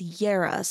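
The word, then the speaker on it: Jera: Julia Lowe, also known as Yera, so that's spelled with a J, J-E-R-A, is a visual artist, UX and UI designer, singer, songwriter, and a witch.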